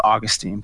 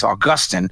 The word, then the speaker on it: Augustine: You know, and he pronounces it the British way. I always thought it was Augustine, but he says Augustine, new temptation.